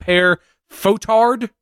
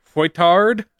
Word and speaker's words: Fouettard: We've got pair fotard, fouettard, fouettard, fouettard, fouet, fouettard. Imagine because fouettard is to whip. Imagine the sound of a whip. Fouet Fouettard. Fouettard. Yeah. Okay. – Pere Fouettard, yet another bad guy with Saint Nicholas drink, Saint Drinkulus.